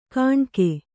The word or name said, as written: ಕಾಣ್ಕೆ